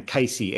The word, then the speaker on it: Cayce: He pronounces his name Cayce, Edgar Cayce.